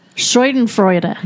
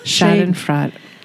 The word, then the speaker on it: Schadenfreude: Schadenfreude. – No. Schadenfreude. I don't know. Let me see it. – Anyway, it's a German word. – It's a German word, and it's spelled S-C-H-A-D-E-N-F-R-E-U-D-E.